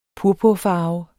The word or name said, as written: purpurfarve